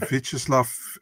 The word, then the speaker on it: Vyacheslav: I would say Sergey Gandoshko, Ustur Magomed, Gadzir Dadov, and Vyacheslav Vasilevsky.